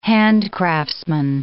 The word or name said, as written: handcraftsman